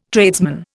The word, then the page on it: tradesman: Indian